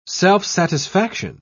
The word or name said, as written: self-satisfaction